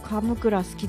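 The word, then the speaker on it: 神座: 私はあの先週も言いましたが、カムクラ好きですね。最初はね大阪だけだったですけどね、今は東京にもありますけど、神に座ると書いてカムクラ、白菜がいっぱい入ってるやつね、すごい大好きです。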